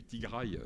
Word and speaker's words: Tigraï: Vous avez donc une rébellion dans une province qui s'appelle la province du Tigré ou du Tigraï, selon la prononciation qu'on adopte.